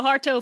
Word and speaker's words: Suharto: Saharto. – Yes, it's pronounced how it's spelled. So, yeah, so Suharto finally resigns as president of Indonesia after 32 years of being a dick.